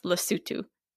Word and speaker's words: Lesotho: It looks like Lesotho, but I think it's pronounced Lesotho.